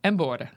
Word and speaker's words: embora: Embora.